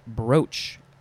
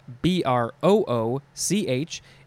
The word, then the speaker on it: brooch: Next is brooch, B R O O C H. It looks like it should be pronounced brooch, but it's not.